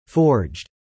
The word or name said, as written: forged